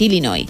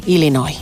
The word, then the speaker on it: Illinois: Pero es Illinois, o sea, si tú lo pronuncias bien y tienes buena dicción, es Illinois. – Illinois.